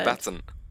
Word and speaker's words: combatant: How do you say that word? – Combatant. It's like the last A is not there.